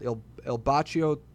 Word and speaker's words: Il Bacio: And there's a pizzeria called Il Bacio Trattoria. – Did you pronounce that right? – I did. Yeah. Okay. I'm Italian, bro.